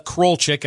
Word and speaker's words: Krolczyk: And by the way, you're at Olivia Krolchek, and I'll spell that Olivia, KROLCZYK underscore on X if folks want to follow you.